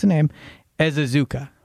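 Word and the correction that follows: Ezeiruaku: You don't get credit until you say the name right. – It's a tough name to pronounce. – Donovan here. – Ezaraku. Ezaraku. – Ezaraku. – Is how you pronounce it. Phonetic.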